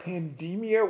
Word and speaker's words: pandemia: I always call it pandemia with sort of a long E, because okay.